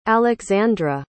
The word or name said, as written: alixandra